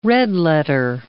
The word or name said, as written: red-letter